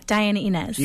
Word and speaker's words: Diana Inez: Yeah, Diana Inez.